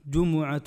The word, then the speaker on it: الجمعة: الجمعة بتثليث الميم، جمعة أو جمعة أو جمعة أو جمعة، هذا تثليث، يعني الميم يصح فيها ثلاث حركات: جمعة جمعة جمعة، والعجب أننا ننطقها بالسكون وليست في اللغة جمعة، اليوم الجمعة جمعة ولا واحدة، يعني ثلاث كلها صح ما جبناها ولا جمعة منها.